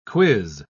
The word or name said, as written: quiz